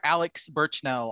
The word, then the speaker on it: Burchnell: Hey, Andrew Cox, people can follow me at Mr. Alex Burchnell on Twitter, Instagram, Facebook.